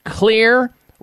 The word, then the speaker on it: Xlear: Clear is spelled X-L-E-A-R. The X stands for xylitol.